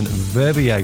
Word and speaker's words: Verbier: I was in Verbier. I think I pronounced that right, have I, Phil? – Yes, although I can't really comment on the pronunciation of the language.